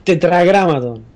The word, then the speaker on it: Tetragramatón: Búscame cómo se dice tetranomicón. – Tetragramatón. – Tetragramatrón, ahí va. Tetragramatrón, tetragramatrón. No, tetragramatrón es nombre de un robot asesino. – Sí. – Yo esto estoy diciendo tetragramatón.